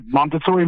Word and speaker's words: Montessori: Do I pronounce it correctly, Montessori? – Right, Montessori method.